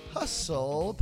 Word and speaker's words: hustle: Welcome to the Mad Hustle Podcast. Is it hustle? It's got a T in there. Hustle Podcast. – The T is silent.